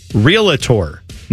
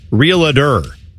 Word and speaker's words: realtor: Dude, realtor is pronounced realtor, not realador. You sound like George W. Bush pronouncing nuclear as nuclear.